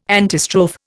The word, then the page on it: antistrophe: Indian